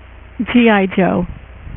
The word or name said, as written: G.I. Joe